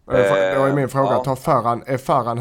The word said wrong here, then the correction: Farhan: Eh, Farhan, är, är det rätt ja. uttal? Farhan? – Ja, eh, med, med min skånska, med skåran där.